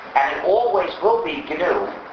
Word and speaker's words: GNU: However, when it's the name of our system, please do not follow the dictionary. Please pronounce it GNU.